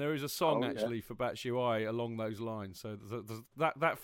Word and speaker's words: Batshuayi: Is that the official pronunciation? – Yes, Batshuayi? I believe so. Batshuayi.